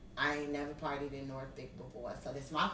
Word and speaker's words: Norfolk: We say Norfolk. – Norfolk. – N-A-W-F-U-C-K. – Norfolk. That's how you say it.